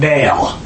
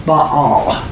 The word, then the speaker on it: Baal: It's not Baal. It's actually two syllables, Baal.